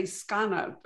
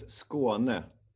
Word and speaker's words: Skåne: I will say Skåne, but that, I'm sure that's not right. – Yeah, no, it's Skåne.